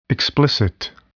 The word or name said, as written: explicit